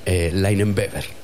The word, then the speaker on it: Leinenweber: Es más alemán. Leinenweber.